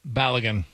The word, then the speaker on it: Balogun: Is that how you say it? Balogun? – Balogun.